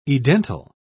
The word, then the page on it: edental: British English